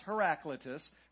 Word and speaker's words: Heraclitus: I had to go to a pronunciation guide on Google to pronounce this name correctly. I was botching it up. So sometimes if I think about it ahead of a sermon, I actually try to figure out how to pronounce some of these words. So it's pronounced Heraclitus.